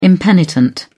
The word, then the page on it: impenitent: British English